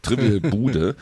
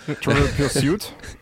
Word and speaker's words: Trivial Pursuit: Wie spricht man das denn aus? Wahrscheinlich spricht man das Bude aus. Trivial Bude. Ja. – Trivial Pursuit.